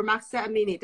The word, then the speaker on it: Mahsa Amini: Justice for Mahsa Amini.